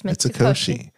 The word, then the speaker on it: Mitsukoshi: Yes, Mitsukoshi.